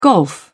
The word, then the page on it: golf: British English